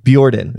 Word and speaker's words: Bjordan: I go Bjordan. Bjordan. Yeah, like Bjork.